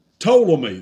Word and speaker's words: Ptolemy: And then Ptolemy, that's P-T-O-L-E-M-Y, you don't pronounce the P, Ptolemy of Egypt.